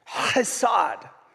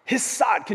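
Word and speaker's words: Chesed (חסד): And the Hebrew word there that is used around goodness and mercy is this word that you have to pronounce differently. Chesed. Chesed. Can you say that with me? It comes like from this like deep, utteral place.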